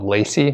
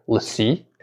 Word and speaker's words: LaCie: It's, it's, a I thought it was called Lacey, but it's uh, apparently LaCie, and I think it's spelled L A C I E.